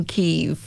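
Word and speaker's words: Kyiv: Although it's Kiev that's now pronounced, isn't it? So Chicken Kiev.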